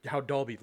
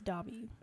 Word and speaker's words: Dobby: Because I never had an issue with how Dolby looked. Is it Dolby, Dobby, Dolby, Dolby? – There's no L. It's Dobby.